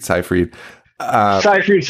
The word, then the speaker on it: Seyfried: You got Amanda Seyfried. I think that's how you say it. Seyfried. – Seyfried sounds good to me.